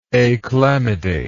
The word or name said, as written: achlamydate